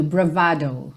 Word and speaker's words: bravado: Então, como ela vem do italiano, a pronúncia fica ali no meio do caminho, bravado, bravado.